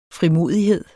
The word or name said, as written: frimodighed